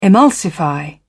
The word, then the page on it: emulsify: British English